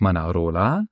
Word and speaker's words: Manarola: Also es sind ganz konkret das Dorf Rio Maggiore, dann haben wir noch Manarola, Cornelia, Vernazza und noch Monterosso al Mare. Habe ich das richtig ausgesprochen? – Esatto, sì, no, le pronunciati bene.